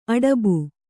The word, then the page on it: ಅಡಬು: aḍabu; Kannada